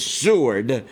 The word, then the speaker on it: Seward: Seward, that's the way they pronounced his name back in those days, Seward, announced the 14th Amendment was ratified by the states, which in turn granted citizenship to ex-slaves.